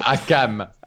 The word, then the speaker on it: Hakam: Il veut monter sur l'échelle, mais l'un des Authors of Pain, c'est Hakim hein, qui monte également. – Le fils du forgeron. – Ouais, c'est Hakam. – Hakam. J'ai... Ouais, écoute, moi, c'est écrit Hakam, donc on va prononcer Hakam, puisque visiblement, Amir Alambar a déjà commencé à écrire la biographie officielle.